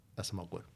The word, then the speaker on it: Acemoglu: And I believe that you are nailing the pronunciation of his last name, Acemoglu.